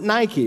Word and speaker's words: Nike: And it comes into English, we pronounce it Nike.